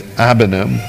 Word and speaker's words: abanim: The word rock, I want to say this right, the word rock is banim, B-A-N-I-M, and the word children is abanim, but the A is almost silent, where it's A-B-A-N-I-M.